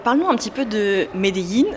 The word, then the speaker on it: Medellín: C'est difficile à prononcer en fait. C'est Là-bas, ils disent Medellín. En fait, c'est un petit mix entre Medellín et Medellín.